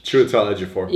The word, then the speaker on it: Chiwetel Ejiofor: Chiwetel Ejiofor Yes, explain a little bit.